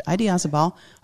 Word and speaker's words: Idiazabal: And Idiazabal, I swear to God, that's how they pronounce it. Idiazabal, a little smoky.